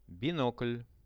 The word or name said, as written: binokl